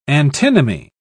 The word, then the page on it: antinomy: British English